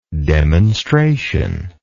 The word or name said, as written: demonstrationist